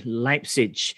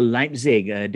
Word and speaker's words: Leipzig: while Man City are at home against RB Leipzig, or Leipzig, depending on your pronunciation.